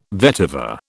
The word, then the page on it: vetiver: British English